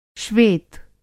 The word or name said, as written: श्वेत